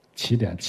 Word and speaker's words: 奇点: 奇点，奇怪的奇啊！我要强调这个，有时候有人把它读成奇点，不是奇偶的奇。当你说奇点的时候，那你必然还有偶点的，没有奇点，也没有偶点，只有奇点，奇怪的奇，就是会这些物质在塌缩在有限的时间内会撞到一个点上，这个点叫奇点。